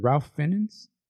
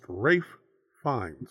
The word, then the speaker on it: Ralph Fiennes: He's played by, was it Ralph Fiennes? – I've been corrected on this gentleman's name. It's pronounced Rafe Fiennes. – Rafe Fiennes. I've heard. – I don't know how you get Rafe out of Ralph, but hey, yeah, yeah.